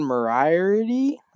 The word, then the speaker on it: Moriarty: I'll go with number four, Erin Moriarty.